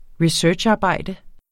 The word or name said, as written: researcharbejde